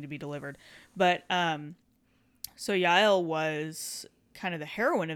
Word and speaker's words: Yael: So if you are not familiar with that story, if you also have not been exposed to that story, it's either J-A-E-L or Y-A-E-L, just depending on how it's spelled. In Hebrew, it's Yael.